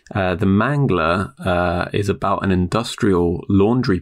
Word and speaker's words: The Mangler: The Mangler, as in like the strangler, rhymes with the mangler? – Correct, yeah.